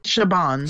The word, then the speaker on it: Chabon: The story was Akiva Goldsman, but Michael Chabon, that's how you say his last name, right? – Chabon.